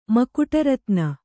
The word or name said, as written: ಮಕುಟರತ್ನ